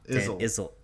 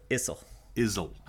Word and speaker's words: Issel: What is it again? Dan Izzo? – Izzle. – Issel. Like shizzle. – Yeah, Izzle. Exactly. Pronounced shizzle.